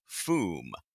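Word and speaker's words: Fooom: The sound effect above is pronounced "foom." The third O is silent.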